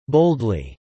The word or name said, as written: boldly